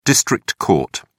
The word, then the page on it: district court: British English